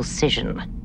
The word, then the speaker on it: scission: And then that's the moment that Janeway cites a Kent State study and then pronounces the word... – A spatial scission. – I had no idea it was pronounced that way.